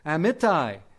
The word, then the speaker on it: Amittai: I know how to pronounce that word in Hebrew, Amittai. I don't really know how to pronounce it in English, so I just say Amittai.